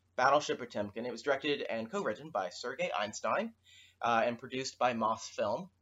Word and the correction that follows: Eisenstein: Yes, we are aware we mispronounced Sergei Eisenstein's name right there.